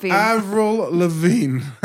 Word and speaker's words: Avril Lavigne: Avril Lavigne.